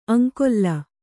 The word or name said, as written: ಆಂಗುಲಿ